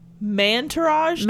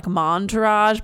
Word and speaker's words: mantourage: Mantourage Mantourage. Dating? I know you want to say it like montourage, but then it takes it's mantourage. Oh, like entourage.